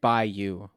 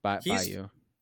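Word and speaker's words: Biliew: And then their recruiting class, like Omaha Bellehue or whatever. I don't know how to say his name, but like, it's Bayou. Like in Louisiana. – LSU. Okay. – Yeah. Bayou.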